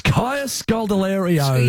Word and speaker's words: Kaya Scodelario: It's Kaya Scodelario's.